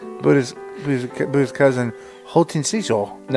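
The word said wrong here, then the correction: Hotei Osho: No. – How you say it? – Hōtei Osho. – Hōtei Osho. – That's right.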